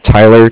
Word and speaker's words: Tyler: My name is Tyler, T-Y-L-E-R. I'm with Bank of Cardiff. It's spelled C-A-R-D-I-F-F, as in Frank Frank.